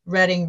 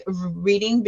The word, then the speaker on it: Reading: A lot of my kids were telling me Reading, Reading, or oh, it's pronounced reading, um, like reading.